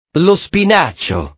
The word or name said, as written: lo spinacio